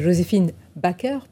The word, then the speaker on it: Joséphine Baker: Ma France est Joséphine Baker, pour le prononcer en français.